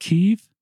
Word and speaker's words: Kyiv: And Dr. Leavitt then was invited as a Fulbright fellow in Ukraine and taught in Kyiv. I say that right. Is it Kyiv? – Yeah. Excellent.